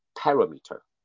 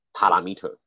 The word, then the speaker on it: parameter: 那我刚刚讲的那个单字呢，正确发音是 parameter，parameter，OK 哈。首先它的音节重音是在第二音节 para，ra，再来 meter 那个 t，它其实是变成的的发音。嗯，parameter，parameter。哦，那一般没有音标做辅助，或是没有声音范本做辅助，就会开始乱念 parameter。或是 parameter，我都听过 parameter 哦。